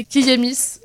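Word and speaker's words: Kiyémis: Rappelle-nous euh, qui a écrit ce texte, s'il te plaît C'est Kiyemis. Euh, du coup, ça s'écrit K-I-Y. E accent aigu, M-I-S, et du coup c'est euh, une blogueuse et podcasteuse super intéressante que je vous conseille d'écouter et de lire.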